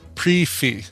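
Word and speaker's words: prix fixe: And the pronunciation is pre-fee. – Oh, pre-fee? – The prixy fix is pronounced pre-fee.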